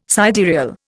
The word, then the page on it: sidereal: Indian